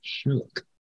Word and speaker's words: Shook: And it's written by my legal name, Franklin Shook.